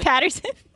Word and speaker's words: Pattinson: they said name wrong. – Patterson. Robert Pattinson.